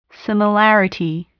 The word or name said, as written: similarity